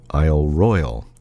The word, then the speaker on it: Isle Royale: Isle Royale, as you probably know, is spelled, the royal part is R-O-Y-A-L-E, and lots of newcomers pronounce it Royale, but it's Isle Royale.